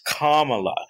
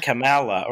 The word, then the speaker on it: Kamala: And then the other guy was so upset, the professional careerist Democrat was so upset because Tucker Carlson didn't pronounce it Kamala. He said Kamala or something like that, which, based on the way it's spelled, seems reasonable.